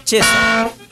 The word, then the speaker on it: Cesa: ¿Cesa? – Sí, así se pronuncia. – Mirella Cesa.